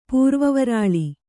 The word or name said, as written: ಪೂರ್ವವರಾಳಿ